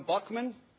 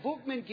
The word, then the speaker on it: Buchman: I used to call him Buckman, and when I started to interview people that knew him, they said, if you're going to talk about our friend, please say his name correctly.